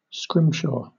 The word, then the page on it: scrimshaw: British English